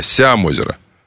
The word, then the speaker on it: Сямозеро: Читайте, пожалуйста, «Сямозеро». Надо ударение на «я». «Сямозеро».